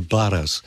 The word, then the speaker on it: Barras: And the song is called The Barras, B-A-R-R-A-S, which is a kind of slang way of Scottish pronunciation of the Barrows, B-A-R-R-O-W-S,